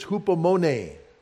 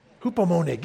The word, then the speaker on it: hupomone: It's the only one I think I can probably pronounce right. It's hupomone. It reminds me of an Italian ice cream or something. Hupomone.